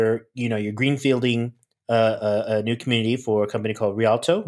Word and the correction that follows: Rialto: Was that correct? – Rialto. – Sorry. That's the emphasis on the wrong syllable.